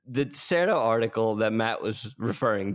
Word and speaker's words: Dexerto: Our next article brought to you by Dextro. – Dexerto. – Is it? – Dexerto. I say Dexerto.